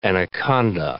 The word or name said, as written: anaconda